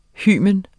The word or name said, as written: hymen